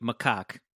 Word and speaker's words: macaque: It feels sad for the monkey, which is pronounced, I just listened to it, macaque. – Macaque. Thank you. – Macaque. It's a macaque.